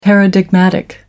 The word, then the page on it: paradigmatic: British English